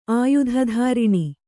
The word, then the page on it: ಆಯುಧಧಾರಿಣಿ: kannada